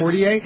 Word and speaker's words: Cordier: We've got retired Air Force Colonel Ken Cordier. Is that how you pronounce his last name? – Yes. – Cordier.